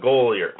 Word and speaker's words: Goeller: It's Karen, K-A-R-E-N. Sometimes there's different spellings of that. And Goyler, and I believe it's G-O-E-L-L-E-R. And so if you Google Karen, K-A-R-E-N, Goyler, G-O-E-L-L-E-R.